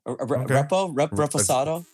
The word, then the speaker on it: reposado: A respo? – Yeah, reposado. – Fuck! I've been saying it wrong the whole fucking time.